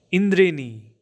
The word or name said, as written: rainbow